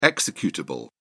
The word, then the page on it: executable: British English